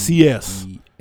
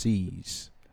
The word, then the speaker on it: CEES: CEES. – C-E-E-S. – Pronounced CS.